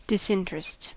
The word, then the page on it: disinterest: American English